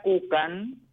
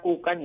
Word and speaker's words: Kucan: En, en Instagram figuro Estela Cucan, Vos sabés cómo se escribe mi apellido. – Sí, K-U-C-A-N. – Sí, Estela Cucan y Estela Coné.